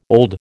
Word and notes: old: American English

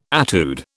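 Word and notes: étude: British English

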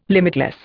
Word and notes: limitless: Indian

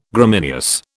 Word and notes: Gramineous: British English

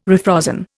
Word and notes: refrozen: Indian

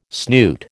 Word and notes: snoot: American English

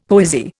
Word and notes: poesy: Indian

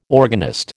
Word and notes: organist: American English

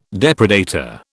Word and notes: depredator: British English

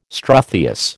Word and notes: struthious: American English

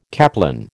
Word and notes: caplin: American English

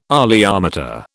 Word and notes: oleometer: British English

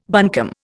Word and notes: buncombe: Indian

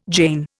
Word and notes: jane: Indian

